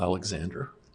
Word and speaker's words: Alexander: I usually go by Michael Alexander,